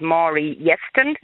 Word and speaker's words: Yeston: I think it's Maury Yeston, is how it's pronounced.